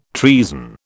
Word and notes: treason: British English

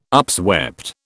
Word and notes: upswept: British English

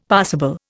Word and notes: passible: Indian

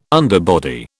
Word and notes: underbody: British English

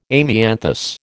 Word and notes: amianthus: American English

